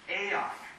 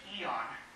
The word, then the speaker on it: aeon: It's the word aeon, or how we often pronounce it in English, eon.